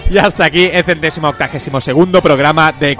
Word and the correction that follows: octogésimo: Y hasta aquí el centésimo octogésimo tercer programa de Caviar Online.